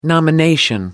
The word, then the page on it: nomination: American English